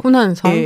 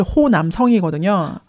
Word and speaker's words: 후난성: 후난성이라고 후난성? 네. – 한국에서는 후난성이라고 이렇게 지금은 지역명 같은 경우에 보통 그 나라 발음으로 네네, 하니까. 그쵸. 예. 근데 한국말로는 사실 호남성이거든요.